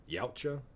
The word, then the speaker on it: Yautja: I don't know how y'all pronounce it, but I call it Yautja.